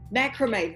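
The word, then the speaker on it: macramé: Now, I hope I don't pronounce this wrong because that would be really embarrassing. But Bluebell Macrame? The weaving. – Macrame, that's it.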